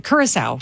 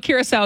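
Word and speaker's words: Curaçao: But you were saying it. Curacao, right? – Yes. Curacao, yeah. That's how the country is pronounced.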